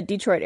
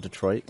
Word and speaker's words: Detroit: Why do you say it like that? Is it not Detroit? – Detroit? – Yeah, you do say it a little weird. – I'm sorry. – Detroit? – Canadian. – And there's your Canadian.